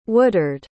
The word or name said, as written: Woodard